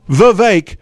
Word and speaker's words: Vivek: His friends call him Vivek, rhymes with cake, but I'll just call him a future president.